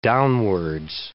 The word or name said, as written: downwards